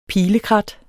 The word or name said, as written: pilekrat